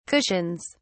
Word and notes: cushions: British English

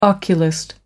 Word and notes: oculist: American English

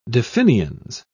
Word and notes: definiens: British English

